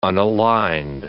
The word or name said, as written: unaligned